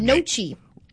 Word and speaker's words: gnocchi: It's got the G in the front. Gnocchi. I said that to see your reaction. Trust me, I know the G is silent. I know you don't say the G, but that was funny.